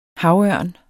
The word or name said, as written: havørn